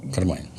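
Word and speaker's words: Carmine: А Сани Корлеоне должен был сыграть такой Кармине Кариди. – Кармайн. Ну, они произносят карманы.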